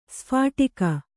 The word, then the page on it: ಸ್ಫೋಟಕ: sphōṭaka